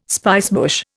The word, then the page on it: spicebush: Indian